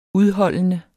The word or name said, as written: udholdende